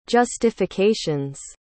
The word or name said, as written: justifications